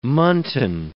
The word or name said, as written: muntin